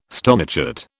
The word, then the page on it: stonechat: British English